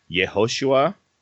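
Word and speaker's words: Yehoshua (יהושע): They would have pronounced it something more similar to Hosea, which means salvation. Or Moses, a lot of time, would call him Yehoshua, which means Yahweh is salvation.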